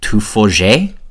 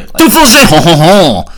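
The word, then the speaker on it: Tiffauges: The name is derived from the French tuffauge, I think is how you say that.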